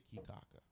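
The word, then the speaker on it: Caye Caulker: And when I tell you, you know, they have keys, little islands off Belize, and we went to Key Cocker. Or I think they technically, it's spelled Cocker, like C-H-A-U-L-K-E-R, but they all say Key Cocker. Like, they don't, they make that a soft E-R.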